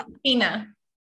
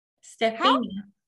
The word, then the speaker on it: Stephenia: We have Stephenia McGee, and I think I just completely mispronounced your name. – Yep. It's all right. Stephenia. – What? – Stephenia. – Stephenia? Mm-hmm. Stephenia. – Stephenia. Mm-hmm. I know that. It doesn't look like that at all.